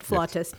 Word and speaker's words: flautist: he had worked with a flutist, or flautist, as they say in England. They're not being pretentious, that's just how they say it, flautist.